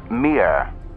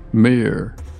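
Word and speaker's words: Mir (Мир): In Cyrillic Russian, these letters are pronounced Mia. – Okay. – Transliterated from the Russian, MNP becomes Mir. – Mir.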